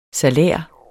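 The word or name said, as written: SALÆR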